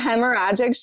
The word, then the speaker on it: hemorrhagic: I had a hemorrhagic stroke. I don't even know if I say that right. – Oh, you're saying it right.